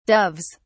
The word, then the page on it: doves: British English